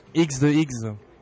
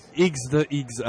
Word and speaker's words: Igz the Igz: Iggs the Iggs. – All right, man. – Yeah. Iggs the Iggs. I'm a photographer. – Easter eggs. – No, Iggs. So you spell it I-G-Z-T-H-E-I-G-Z.